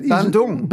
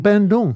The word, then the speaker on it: Bandung: Bandung. – Bandung.